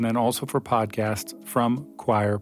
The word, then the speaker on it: Quoir: It's spelled Q-U-O-I-R, but it sounds like choir.